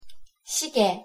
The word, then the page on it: 시계: Korean